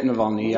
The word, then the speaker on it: Navalny: Is that the way you pronounce it? – Yep, Navalny. Yeah.